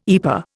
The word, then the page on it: EPA: Indian